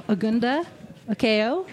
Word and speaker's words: Agunda Okeyo: Today's guest, we have Agunda Okeo. Am I saying that right? – Yes, you are.